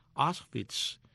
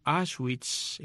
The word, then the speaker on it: Auschwitz: De manera que Auschwitz, que es la manera, Auschwitz, que es la manera de pronunciarlo en alemán o en polaco, Auschwitz, en español o en inglés, se escribe A-U-S-C-H-W-I-T-Z.